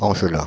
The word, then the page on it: California: /ˌkæl.ɪ.ˈfɔɹ.njə/; /ˌkæl.ɪ.ˈfɔɹ.ni.ə/; American English